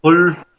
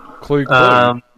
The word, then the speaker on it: Klugh: Her last name is pronounced Klugh. Oh. – Klugh.